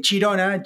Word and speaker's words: Girona: en catalán, eh, Chirona, Chirona, se pronuncia de esta manera así un poco Chirona,